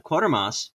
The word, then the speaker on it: Quatermass: Quatermass, it's spelled like Quarter, but without the first R. Quarter, Quarter. So Quatermass is a British progressive rock band from London.